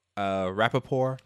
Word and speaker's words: Rapaport: We have Michael Rapaport. – Rapaport? – Sorry, what did I say? – Oh, no, you said it right.